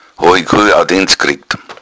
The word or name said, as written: audienz